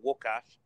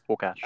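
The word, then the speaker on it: Łukasz: it's spelt Lucas. The anglicised way, it looks like Lucas, but you actually pronounce it Wukash. – Wukash.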